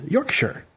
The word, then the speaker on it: Yorkshire: He says the proper way to say Yorkshire is Yorkshire, as in little terrier, like a Yorkshire Terrier.